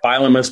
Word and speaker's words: Bielema: I want to congratulate him on Brett Bielema's performance on Saturday. – You're mispronouncing his name. It's Bielema.